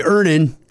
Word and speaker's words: Earnin: Earnin. E-A-R-N-I-N is pronounced Earnin. I don't know how else you would pronounce it, but they really want it clear that that's how to pronounce it.